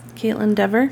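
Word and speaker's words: Kaitlyn Dever: Oh, Caitlin Dever.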